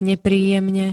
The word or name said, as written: nepríjemne